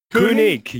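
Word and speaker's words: König: It's it's nice to welcome Dominik, and I'm going to butcher it, even though I, I asked how to pronounce it, but Koenig, Koenig, Koenig. yes.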